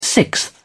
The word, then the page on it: sixth: British English